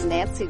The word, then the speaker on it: Nancy: P-L-A-T-T. – And your name? – My name is Nancy Johnson. – Nancy, can you spell that? – N-A-N-C-Y.